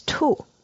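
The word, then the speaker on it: Tu (兔): The Chinese pronunciation of rabbit is Tu.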